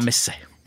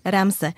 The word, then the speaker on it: Ramse: Ale Ramzesovi teda, alebo ako ste hovorili, že to mám vyslovovať? – Ramse. – Ramse.